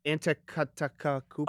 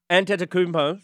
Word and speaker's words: Antetokounmpo: And Giannis, and how do you pronounce that last name? – Antetokounmpo. – Oh, bro! – I'm the sports guy. – I know. Antetokounmpo. – Yeah, there you go.